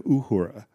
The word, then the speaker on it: Uhura: Yeah, Shatner says Lieutenant Uhura instead of Uhura.